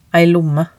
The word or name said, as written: ei lomme